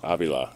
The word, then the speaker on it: Avila: Avila.